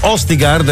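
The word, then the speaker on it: Østigård: Mikael Østigard, la pronuncia è giusta? – Sì.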